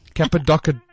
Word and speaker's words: Cappadocia: So I think it's Cappadocio. Wow. Cappadocio. – Okay. Cappuccino. I'm just going to. – Cappadocia.